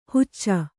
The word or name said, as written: ಹುಚ್ಚ